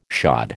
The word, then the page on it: shod: American English